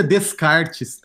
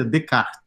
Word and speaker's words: Descartes: Que não pronuncia Descartes, tá, gente? Pronuncia Descartes, René Descartes. René Descartes, cristão, católico.